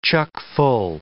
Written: chock-full

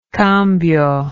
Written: cambio

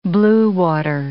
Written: blue-water

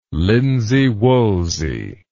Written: linsey woolsey

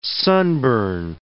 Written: sunburn

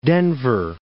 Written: Denver

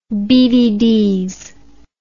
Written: beeveedees

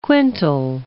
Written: quintal

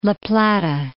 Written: La Plata Peak

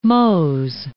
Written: mots

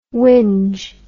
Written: winge

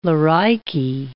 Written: loricae

